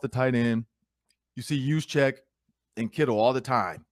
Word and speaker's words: Juszczyk: Is it Chizik or Juszczyk? – Juszczyk.